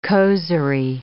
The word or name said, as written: causerie